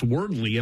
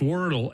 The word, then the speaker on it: Wordle: I'm glad I never got on it because I always thought it was pronounced Wordly. And I, hey, did you play Wordly? And people go, you don't even know how to say the damn game right, the name. – You're never going to get the word. – No, not even close. Anyhow, it's Wordle.